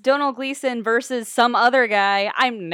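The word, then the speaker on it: Domhnall Gleeson: It's Donal Gleason. – Donal Gleason, he was great too.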